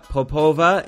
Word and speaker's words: Popova: So I'm really happy to have Maria Popova.